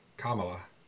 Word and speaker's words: Kamala: Kamala. – Oh, sorry. – It's all right. – It's the accent. Yes. It's the accent. – Put the right emphasis on the right syllable.